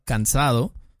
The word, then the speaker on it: cansado: Por ejemplo, otra vez la palabra cansado. Cansado. Eh, a veces escucho como cansado cansado O cansado Como que se comen esta A, can.